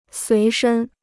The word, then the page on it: 随身: suí shēn